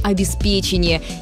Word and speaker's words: обеспечение: Так что пока смело говорите «обеспечение» и будьте уверены, сегодня это правильно.